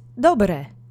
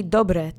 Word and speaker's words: dobre: The next word is dobre. We've seen this before. It means good. Let's say dobre together three times. Accent is on the first syllable.